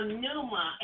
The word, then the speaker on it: pneuma: Pneuma. It starts with a P. P-N-E-U-M-A. The P is silent, as in like pneumonia. The P is silent, but it's spelled P as in Paul, N-U-E-M-A, pneuma.